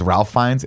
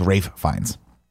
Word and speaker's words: Ralph Fiennes: Everyone thinks it's Ralph Fiennes. It's Rafe Fiennes. Rafe Fiennes is how you say his name.